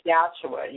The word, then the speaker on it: Yahshua: you pronounce it Yahshua. You know, my name is Yahshua. You can pronounce it as Yahshua, which is Hebrew, you know, for Jesus. But I just say Yahshua. My name is Yahshua. – Yahshua. Like, okay, got it. – Yeah, like Joshua.